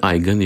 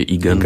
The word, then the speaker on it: iGen: Właśnie jak się to wymawia? – Pewnie powiedzielibyśmy Eigen, jeśli chcielibyśmy być zgodni z pokoleniem, o którym mówimy, ale jeśli ktoś powie Igen, to pan nie będzie patrzył strasznym wzrokiem.